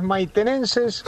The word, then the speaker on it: maitenenses: Escuchando los maitenses. – En realidad es maitenenses, sí.